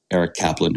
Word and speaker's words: Aric Caplan: So if you feel like that's something good, look me up because my name is spelled A-R-I-C-C-A-P-L-A-N, Eric Kaplan.